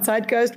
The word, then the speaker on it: Zeitgeist: Well, your pronunciation on Zeitgeist was perfect.